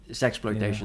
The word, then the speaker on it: ZAxploitation: So ZAxploitation? – Yeah, I think you still have to pronounce it in English because of the exploitation part. ZAxploitation is probably right.